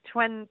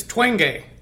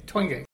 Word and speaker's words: Twenge: Jean, how do you say her last name? You said Twen. – I believe it's Twenge. Twenge.